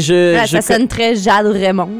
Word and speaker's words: Jade Raymond: Jeanne Raymond. – Jade Raymond, exactement.